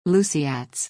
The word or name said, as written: Luceats